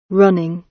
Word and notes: running: British English

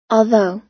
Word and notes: although: American English

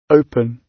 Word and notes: open: British English

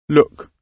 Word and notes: look: British English

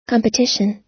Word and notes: competition: American English

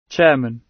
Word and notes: chairman: British English

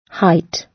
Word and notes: height: British English

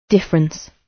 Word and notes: difference: British English

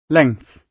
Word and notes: length: British English

